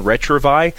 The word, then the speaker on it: Retrouvaille: It's from a French origin, Retrovi. It is spelled Retrouvaille.